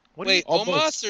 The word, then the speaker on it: Omos: Y'all keep saying the words almost. – Omos is his name, and I have loved him from the beginning. Karrion Kross. – Omos or Nomos? – I thought we were talking about Karrion Kross.